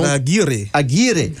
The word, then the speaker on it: Javier Aguirre: Javier Aguirre.